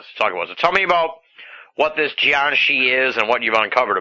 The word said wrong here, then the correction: Jiangshi: Well, the correct pronunciation is Jiangshi. – Jiangshi.